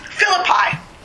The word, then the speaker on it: Philippi: Did Paul visit how do you pronounce it? Philippi? – Philippi.